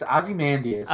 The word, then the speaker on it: Ozymandias: It's Ozymandias. – Oh, okay. I didn't hit the A.